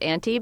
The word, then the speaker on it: Anti: I mean, Julia pronounces it Anti, because then I picture that it's A-U-N-T-I-E and it's a nice little Anti record.